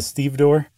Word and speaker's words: stevedore: It's the boss of the longshoremen, the actual workers, the one who's like the head, the foreman, is a stevedore. – Okay. You said that earlier, and I pretended like I knew what you meant. So It's spelled exactly like it's pronounced, too.